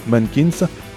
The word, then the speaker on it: MNNQNS: Juste après, on écoutera MNNQNS, prononcé Mankins, groupe français. If Only the Cold, ce sera le morceau, rock français déjanté et chanté en anglais. Mankins, c'est un groupe français qui nous donne envie d'écouter du rock.